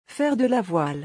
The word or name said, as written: Faire de la voile